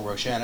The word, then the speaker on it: Roshan: No, the first time, the first episode, I said I am Mitchell Roshan, and I was only one to say my Roshan.